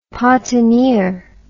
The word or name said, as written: Patenier